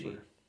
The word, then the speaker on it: Koshy: How do you pronounce her last name? – Liza Koshy. She's a YouTuber. Koshy.